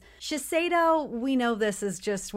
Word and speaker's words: Shiseido: I'm trying to like make sure I say it properly. Shiseido. – Yep. – See, I thought I had been saying it that way, but apparently I've been saying Shiseido.